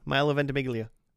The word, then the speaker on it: Milo Ventimiglia: Milo Ventimiglia.